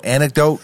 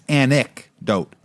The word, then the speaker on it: anecdote: One thing I want to say is you love to say anecdote, but it's anecdote. – I thought I pronounced a C in that. – Nah. – Anecdote. – Yeah, there you go.